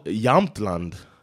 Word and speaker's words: Jämtland: And it's called Jämtland locally. That's how they say it, Jämtland.